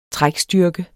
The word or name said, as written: trækstyrke